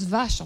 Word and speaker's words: Wache: Alors ces guérites portaient la mention « Wache », ce qui signifie « garde » en allemand et qui se prononce « vache » en français.